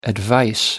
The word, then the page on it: advice: American English